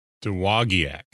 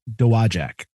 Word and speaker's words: Dowagiac: I think we're going four syllables on this, and I think it's split up. The first four letters are two syllables, so Dowagiac.